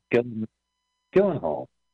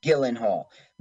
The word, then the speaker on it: Gyllenhaal: Maggie, how do you say it? Gyllenhaal? What is it? – Jake, right? Or Maggie? – Yeah, what's the last name? – Gyllenhaal. – Gyllenhaal.